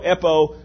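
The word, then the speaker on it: epo (εἴπω): That whosoever should epo, E-P, long O, epo, whoever would say.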